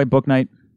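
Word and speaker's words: Bouknight: Am I saying that right, by the way, Booknight? – Yep.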